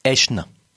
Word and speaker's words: Aeschne: Qui s'écrit A-E-S-C-H-N-E. Là, je vais dire au Scrabble, tu mets du point. Et qui se prononce Aeschne.